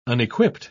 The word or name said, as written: unequipped